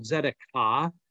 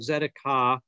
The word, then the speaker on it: Tzedakah: And you'll see on your notes there, that's a Hebrew word that is called Tzedakah. The T is silent. Tzedakah is the idea of justice.